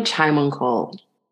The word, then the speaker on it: Chaimongkol: Trina, pronounce your last name. I feel terrible. I don't want to butcher it. So will you say it for me? No, it's okay. Trina Chaimongkol. – Chaimongkol? – Yeah, that's it. Chaimongkol, okay. Like a tea, chai.